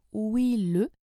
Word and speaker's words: wheel: Sa wheel, ça se prononce wheel-le, et ça veut dire roue.